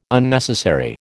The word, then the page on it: Unnecessary: American English